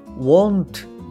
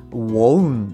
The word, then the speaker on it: won't: これは won't ではなくて won't っていうふうに発音します。